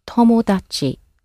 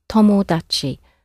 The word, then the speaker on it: tomodachi: The word for friend in Japanese is tomodachi, tomodachi. Maybe you've got a friend called Tom, who made an odd achievement, tomodachi. How do you say I go? – Ikimasu.